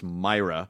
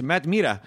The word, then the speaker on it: Mira: But Matt, one of Matt's biggest crosses to bear is that his last name is pronounced Myra. – Yeah. – But of course, in Los Angeles, Mira. – Where there's a large Latino population, it's Matt Mira.